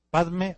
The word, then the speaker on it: Padme: Padme o Pema es loto. Pema es la pronunciación tibetana, pero el nombre en realidad es Padme. Padme en hindú, Pema en tibetano.